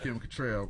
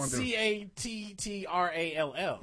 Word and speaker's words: Cattrall: I thought it was Kim Cattrall, but all right. – Well, it's spelled C A T T R A L L. – Yeah. So and that's usually. Cattrall. That's usually all.